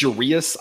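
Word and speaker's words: Urías: I don't know why I've been saying Urias. It's Urias.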